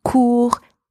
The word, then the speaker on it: court: And the third word, court, spelled C-O-U-R-T, means short, as in short in length or in time. And again, same pronunciation as the word before because the final consonant T is silent in that word.